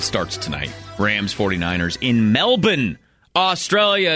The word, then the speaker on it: Melbourne: This the way to say it is Melbourne. So when you hear people saying Melbourne, there's no R in there. It's silent.